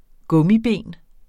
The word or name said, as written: gummiben